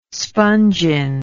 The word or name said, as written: spongin